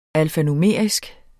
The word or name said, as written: alfanumerisk